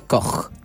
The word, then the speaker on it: Koch: ¿Están al tanto de la existencia de Alejandra Koch? – Sí, sí, vamos. Sí, sí. – El que no es una forma como sobreactuada. – Correcto, en la forma, la pronunciación correcta del apellido en alemán es Koch.